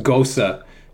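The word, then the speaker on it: Gose: It's so, I think like it's actually pronounced Gosa. – Gosa. – It's a German word.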